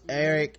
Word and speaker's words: Ayrryk: Eric, but it's spelled like A-Y-R-R-Y-K.